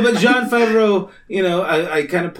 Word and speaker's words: Jon Favreau: One is, Jon Favreau, is that his name? – Yes. – I think that's how you pronounce it. – Yeah, Jon Favreau.